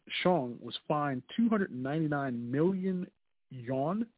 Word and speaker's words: yuan: Am I pronouncing that correctly? – Yen. – Yen? They spell it Y-U-A-N. – Yen. – It's not Y-E. – Right. No, it's still yen. It's still yen. – That's not spelled yen. – Oh, I'm sorry. It's yuan. It's yuan.